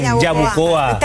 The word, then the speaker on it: Yabucoa: ¿Me equivoqué o lo dije bien? Lo dije bien, Yabucoa.